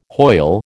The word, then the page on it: Hoyle: American English